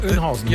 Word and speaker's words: Oeynhausen: A2 Dortmund Richtung Hannover zwischen Herford Ost und Bad Kreuz, Oien, Oi. Wie heißt das? Wie würdest du es denn aussprechen? – Was denn bitte? Kreuz? – Kreuz bei Oeynhausen. Auch so? – Oeynhausen, ja. Genau.